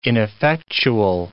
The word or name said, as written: ineffectual